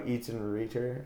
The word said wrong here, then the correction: Eitan Reiter: how do you say that? – I think it's Eitan Reiter. – Eitan Reiter, awesome.